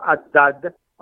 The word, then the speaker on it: Haddad: Decimos Haddad, aunque se escribe H-A-D-D-A-D. En inglés se diría Haddad.